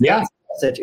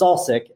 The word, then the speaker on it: Salsich: It's Salsich. It's Salsich.